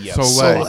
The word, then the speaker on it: Soleil: Soleil. – It's a silent L.